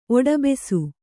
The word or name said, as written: ಒಡಬೆಸು